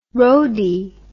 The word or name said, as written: rhode